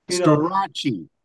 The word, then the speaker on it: Storace: Storaci. – Yeah, Tommy Hendrickson says, hey, Storaci. – How do you say it? How do you say it? – Well, it's like Liberace, Versace, Storace.